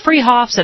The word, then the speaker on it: Freehoff's: Spelled Freehoff's, pronounced Freehoff's at 33rd and Arapahoe in Boulder.